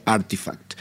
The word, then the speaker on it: RTFKT: RTFKT, que en realidad se pronuncia Artifact.